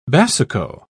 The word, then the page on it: basuco: British English